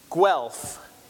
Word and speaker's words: Guelph: And it's pronounced Guelph, G-U-E-L-P-H, Guelph.